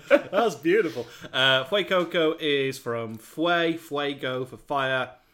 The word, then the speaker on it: Fuecoco: I haven't quite landed on my pronunciation of it yet. – I thought it was Fuecoco. – I don't want to put the accent on. It sounds like I'm being disrespectful, but yeah.